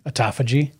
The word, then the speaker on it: autophagy: Still not sure I'm saying it right. – You are. – Autophagy? – Yep.